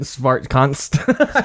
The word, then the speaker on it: Svartkonst: You see it, Svartkonst. – Start- yeah, Smart- yeah. I guess it's right? Oh my god, he pronounced it right too.